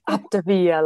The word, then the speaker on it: Optavia: Is that how you say it? – Optavia, like Mamma Mia.